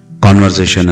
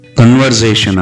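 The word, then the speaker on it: conversation: ಈಗ ಇನ್ನೊಂದು ಕಾನ್ವರ್ಸೇಷನ್ ಅನ್ನ ತಗೊಳ್ಳೋಣ. ಈ ಕಾನ್ವರ್ಸೇಷನ್ ಅನ್ನೋ ಪದದ ಉಚ್ಚಾರಣೆ ಅಭ್ಯಾಸ ಮಾಡಿಕೊಂಡಿದ್ದೀರಿ? ಮರ್ತಿಲ್ಲ, ಹೌದಲ್ಲ? ಕನ್ವರ್ಸೇಷನ್ ಅಂತ ಹೇಳಬಾರ್ದು, ಕಾನ್ವರ್ಸೇಷನ್ ಅಂತ ಹೇಳಬೇಕು. ಕನ್ವರ್ಸೇಷನ್ ಬರಬಾರ್ದು, ಕಾನ್ವರ್ಸೇಷನ್ ಅಂತ ಬರಬೇಕು, ಸಂಭಾಷಣೆ.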